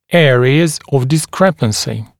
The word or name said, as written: areas of discrepancy